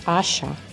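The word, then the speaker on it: ꜥšꜣ: We also have the beautiful lizard, which we pronounce Asha.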